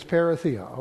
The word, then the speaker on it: parousia: This word coming in the Greek comes from the word parousia. Now, I'm not going to argue over how to pronounce it. I'm just wonderful I can pronounce English, okay? But for tonight, it's parousia, okay?